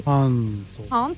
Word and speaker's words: Hansel: Hansel.